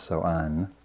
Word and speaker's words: Samhain: And Halloween, according to various sources, has its origins in the ancient Celtic festival known as, it's spelt Samhain, but I think it's pronounced So'an, which is derived from Old Irish and means summer's end.